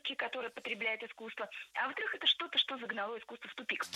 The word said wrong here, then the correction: загнало: Загнало. Неверно. Правильный вариант – загнало. И здесь есть одна общая тенденция. В проблемных словах прошедшего времени среднего рода, то есть там, где мы сомневаемся, ударение на окончании не ставим категорически. Поэтому только загнало.